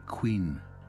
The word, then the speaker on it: Quinn: Her name, I learnt, was Quinn, pronounced locally Queen.